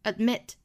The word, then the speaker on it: admit: admit 这个发音呢，中间的这个的也是要憋那么一下的哈，不是 admit，而是 admit， admit， acknowledge， acknowledge，这里面这两个爆破音都是不爆破的。